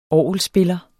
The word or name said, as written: orgelspiller